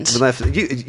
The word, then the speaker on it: Maleficent: How do you pronounce it? – Maleficent.